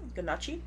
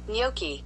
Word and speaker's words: gnocchi: Were we so wrong? What? That's how you spell it, right? – Yeah. – Gnocchi. – Damn, gnocchi. I was so, we were so wrong. Gnocchi? Gnocchi? That's what gnocchi. I said? – We were saying it's ganache.